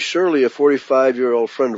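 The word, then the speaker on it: Shurley: Shirley, spelled with a U, S-H-U-R-L-E-Y, I'll pronounce it Shirley, responded that his gut feeling would be yes, he would be capable of that.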